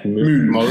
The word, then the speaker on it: Møl: I think the French usually get pretty close with Mill. But I think, Moll is perfectly acceptable.